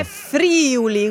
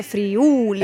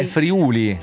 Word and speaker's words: Friuli: O dire Friuli con l'accento sulla prima I. No, è Friuli. – È Friuli. – Con l'accento sulla U.